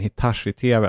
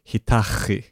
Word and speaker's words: Hitachi: Det klassiska i familjen var när min mamma envisades med att vi hade köpt en Hitachi-tv, alltså japansk. Hon uttalade det som Hitachi. Och sen skrattade vi så mycket och sa att ja, då får jag ändra, men då började hon säga Hitachi. Ja, Sir Gavin.